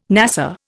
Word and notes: NASA: Indian

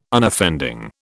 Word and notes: Unoffending: British English

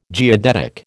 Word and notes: geodetic: American English

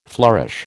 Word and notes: flourish: American English

